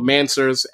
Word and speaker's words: Mansers: Um, it's called Mansers. M A. Uh, it's, spe- it's spelled like M A N, like answers. Spelled right? like answers, but with an M.